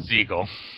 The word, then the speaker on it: Segel: And Jason Segel, or Segal? How do you say that guy's last name? – Segal.